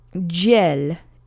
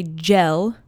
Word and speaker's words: dziel: So dziel is how you say it. Now make sure you don't say gel. Make sure you have that little y sound in there too. It's not gel. It's not like the word G-E-L in English.